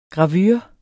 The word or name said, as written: gravure